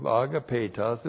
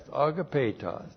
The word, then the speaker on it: agapetos: Well, we have a verbal adjective, agapetos, and we've seen this many times in our classes. A-G-A-P, long E-T-O-S, agapetos. Now, agapetos is used for the special use in the church age describing fellow members of the royal family of God.